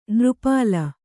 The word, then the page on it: ನೃಪಾಲ: nřpāla